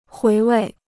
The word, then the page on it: 回味: huí wèi